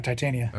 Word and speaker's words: Titania: The next one would be, is it Titania? Is that how you say it? – That's how I say it. Yeah, Titania.